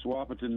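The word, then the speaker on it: Wahpeton: Wapton, North Dakota. Am I saying it right? – Yes, Wapiton, North Dakota.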